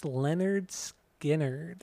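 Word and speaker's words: Lynyrd Skynyrd: So my number two is the first record, pronounced Leonard Skinner.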